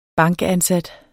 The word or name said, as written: bankansat